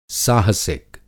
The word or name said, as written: साहसिक